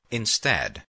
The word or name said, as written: instead